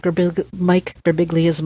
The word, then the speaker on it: Birbiglia: I've become a big fan of Mike Birbiglia's monologues over the years. Is it pronounced Birbiglia? – Yes, Birbiglia.